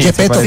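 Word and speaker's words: Geppetto: Y además que el viejo se parece a Gepetto, ¿es que se llama? – Sí, Gepetto.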